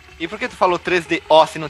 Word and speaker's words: 3DO: Tem podcast brasileiro que xinga quando as pessoas pronunciam errado e o cara fala 3DO e não tem acento no O na versão brasileira. Então é 3DO.